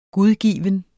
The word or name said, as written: gudgiven